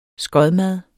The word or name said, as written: skodmad